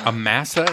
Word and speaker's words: Amasa: Amasa is how you pronounce it.